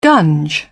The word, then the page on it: gunge: British English